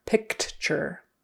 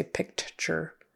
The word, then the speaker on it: picture: But we don't really say picture. Picture. Picture. We might pronounce that C, that K sound, picture. But typically that T in the middle is going to sound like a CH. And U-R-E is going to sound like E-R. Picture. Picture. Picture. Picture. With the accent on the first syllable.